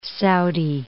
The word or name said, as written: Saudi Arabia